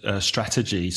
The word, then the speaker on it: strategy: 3 syllables? Yep. Stress the first syllable. Strategy. Mm-hmm. Strategy. The adjective is strategic. Also 3 syllables, but be careful. The stress changes.